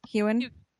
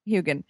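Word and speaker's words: Heughan: How do you say that guy's name, Sam? What's his face? Hewen? – Hugin.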